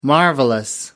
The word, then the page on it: marvelous: British English